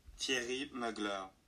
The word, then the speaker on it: Thierry Mugler: Thierry Mugler.